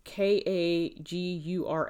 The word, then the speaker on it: Kagura Jishi: The tree peony is called Kajura Jishi. That's Kagura Jishi.